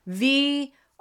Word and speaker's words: Viognier: Start with the letter V. You can even do this like a little cheerleader cheer. Make a V with your hands. Then crisscross your arms like you're hugging the front of yourself. Own it. And then, yay, raise your hands above your head because we get so excited about it. Viognier. That's how we pronounce it.